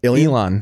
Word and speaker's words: Elon: Is that Elon.